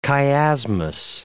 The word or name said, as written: chiasmus